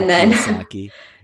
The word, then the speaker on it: Kiyosaki: Robert Kiyosaki.